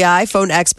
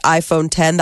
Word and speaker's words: iPhone X: It's the iPhone X, but it's pronounced iPhone 10.